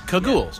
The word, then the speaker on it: Kagools: K-A-G-O-O-L-S. – Cagoules, yeah. Just like it sounds.